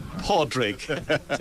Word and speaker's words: Pádraig: I think what struck me about Pádraig, did I say that right? Good. – Pádraig, to be exact.